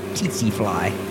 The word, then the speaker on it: tsetse fly: I've heard this many, many times, and I've always pronounced it tsetse fly. Is that correct? – That is fly. That is fly. – That is correct.